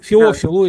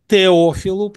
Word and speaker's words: Феофил: Феофилу, если уже с греческого точно ударение ставить, Феофилу, или мы бы сказали Теофилу, потому что для нас все-таки мы прочитываем все-таки по такой традиции Дезидерия Разма Роттердамского тету как Т.